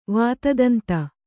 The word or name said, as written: ವಾತದಂತ